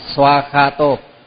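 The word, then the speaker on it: svākkhāto: Rồi bây giờ học bài mới su ak kha tô thì người ta viết nối lại đó là swa kha tô sva sva kha tô, nhưng mà mình đọc là swa kha tô swa kha tô, đọc là swa kha tô.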